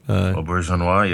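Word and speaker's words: Auberjonois: It also has Rene Aubergeois. Is that how you say his name? – Aubergeois, yeah.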